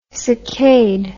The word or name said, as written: succade